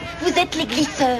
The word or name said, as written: glisseurs